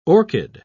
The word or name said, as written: orchid